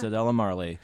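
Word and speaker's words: Cedella: Sadella Marley. Did I say that right, Raquel? – Yeah. – Sadella Marley, the daughter of Bob Marley.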